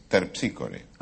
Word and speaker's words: Terpsícore: Esther Píscore, dijo. – No dije Esther. – ¿Cómo que no dije? – Dije, es Terpsícore. – Ah, claro. Esther Píscore. Esther Píscore. Esa es la pronunciación griega, claro.